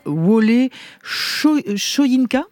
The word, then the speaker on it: Wole Soyinka: Ilana, la semaine dernière, Wole Soyinka, c'est comme ça que ça se prononce ? – Ça se prononce comme ça, oui.